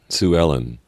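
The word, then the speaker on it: Suellen: Is spelled capital S. I guess I didn't need the capital, did I? U E L L E N. – Sue Ellen. – I think it's Sue Ellen.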